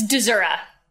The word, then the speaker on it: Dezura: It's Dezura. That is not how the Poles say it, but my family has long since anglicized.